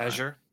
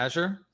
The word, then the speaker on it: Azure: Azure. – Right? Azure.